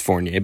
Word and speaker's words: Fournier: Like, a lot of people call me Ryan Fournier or Forner or something like that. It's French. It's Fournier, but I'm not going to get into all that confusion, so I called it the Liberty Feed.